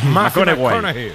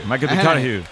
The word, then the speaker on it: McConaughey: Hay que ir corrigiendo la pronunciación. – McConaughey. McConaughey. Bueno, pues el Matías.